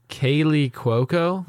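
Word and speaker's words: Kaley Cuoco: Is it Kaylee Cuoco? – Yes. – That's how you say it.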